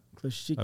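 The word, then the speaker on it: Klishchiivka: But now what you're seeing on the southern portion of Bakhmut, around Klishvika, um, I'm, by the way, did I say that correctly? – Klishivka. – Klishivka. I believe it's Klishivka.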